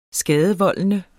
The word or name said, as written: skadevoldende